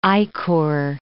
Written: ichor

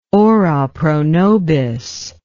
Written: ora pro nobis

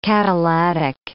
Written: catalatic

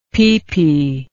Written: pipi

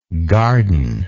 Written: gardenlike

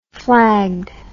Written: flagged

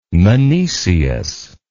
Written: menoeceus

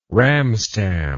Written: ramstam

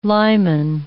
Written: limen

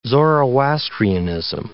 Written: Zoroastrianism

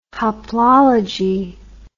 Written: hoplology

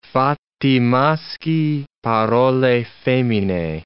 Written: fatti maschii, parole femine

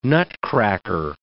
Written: nutcracker

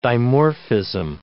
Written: dimorphism

